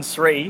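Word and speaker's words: Sri: Now, one of the reasons I wanted to play that is that Jonathan Sree, who's the... that is how you pronounce his last name, isn't it? – I hope so. – Yeah, Apologies, Jonathan. – It is.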